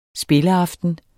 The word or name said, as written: spilleaften